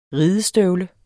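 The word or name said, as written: RIDESTØVLE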